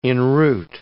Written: enroot

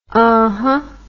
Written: huhuh

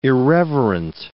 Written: irreverent

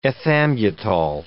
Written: ethambutol

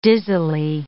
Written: dizzily

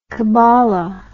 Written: kabbala

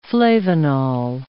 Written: flavonol

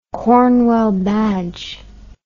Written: Cornwell Badge

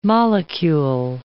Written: molecule

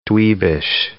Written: dweebish

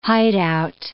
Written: hideout

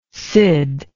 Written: cid

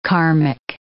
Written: karmic

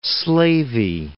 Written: slavey

